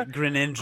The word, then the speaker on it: Greninja: And then the only, I think, truly new character is Greninja? Did I pronounce that correctly? Greninja? – Yeah, Greninja.